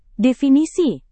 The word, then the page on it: definisi: Indonesian